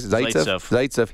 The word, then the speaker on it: Zaitsev: Zaitsev.